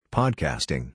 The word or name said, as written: Podcasting